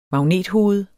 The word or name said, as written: magnethoved